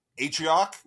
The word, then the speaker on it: Atrioc: And it was like, this is what it said, Twitch streamer Atrioc. Is that how you say it? – Yeah.